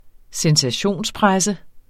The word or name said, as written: sensationspresse